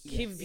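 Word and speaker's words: Kiwior: I don't know how. So is it a V? – I think the Polish pronunciation is Kivior. Yes. Yeah.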